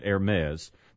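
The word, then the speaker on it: Hermès: It should be Hermes, but you know they're French, and so they pronounce it Hermes.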